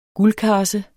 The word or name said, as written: guldkarse